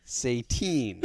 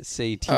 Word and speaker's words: Saytien: S-A-Y-T-I-E-N. – Satan. – So it's pronounced Satine. – Satine. S-A-Y-T what? – S-A-Y-T-I-E-N. Satine. – Oh, boy. – I-E-N?